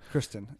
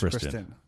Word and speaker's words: Christine: I would pick him up before I picked up Christian Michael or Christine Michael. How do you say that guy's name? – Kristen. It's Kristen.